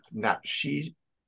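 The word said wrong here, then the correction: Napheesa: You mean a FISA Collier? – Thank you. I knew you'd ha know how to say it.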